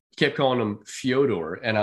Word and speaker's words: Fyodor: But apparently, that's, according to him, that's how you pronounce his name, Fyodor. And I don't see a Y in there, but okay, you know, I respect the man's wishes.